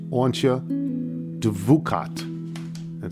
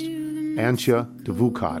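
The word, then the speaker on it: Antje Duvekot: It's by a woman whose name is pronounced Oncha Duvukat.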